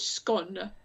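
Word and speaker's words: Skåne: I thought you might like to know that I messaged my Swedish friend late last night to find out how to pronounce Skane. It's scone, apparently.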